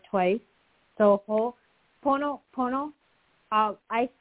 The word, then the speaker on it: Ho'oponopono: I'm not sure if I'm pronouncing it correctly, but do you know the Ho'oponopono prayer? Am I saying that correctly? – Mm-hmm.